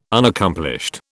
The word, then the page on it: Unaccomplished: British English